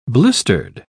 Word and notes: blistered: British English

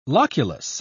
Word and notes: loculus: British English

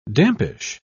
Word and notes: dampish: British English

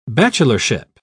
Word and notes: bachelorship: British English

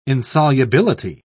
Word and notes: insolubility: British English

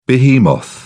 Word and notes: behemoth: British English

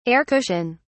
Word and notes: air cushion: British English

